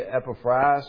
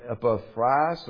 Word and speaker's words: Epaphras: You do hear it pronounced differently. I think our English brethren would say Epaphras or something of that nature.